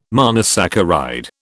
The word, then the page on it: monosaccharide: British English